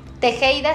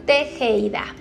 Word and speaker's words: Tejeida: Mi nombre se escribe con doble S, Melisa Tejeida se escribe con T de Tito, E de elefante, J de jamón, E de Ignacio, E de elefante, perdón, y Ignacio, D de dedo y A de árbol. Tejeida.